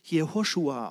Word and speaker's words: Jehoshua: Der Name Josua wird im Hebräischen übrigens Jehoshua ausgeschrieben oder auch ausgesprochen. Joshua ist nur die Kurzform von Jehoshua.